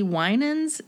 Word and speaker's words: Winans: BB and CC Winans.